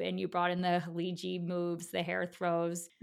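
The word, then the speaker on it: Khaleeji: Do I say it correctly too? There's no actual ka in the beginning, right? – Haliji, yeah, that's correct. Okay. In Egypt, people say Haligi often, but that's because they don't pronounce the J sound. They do hard Gs. But in the Haliji region, they say Haliji.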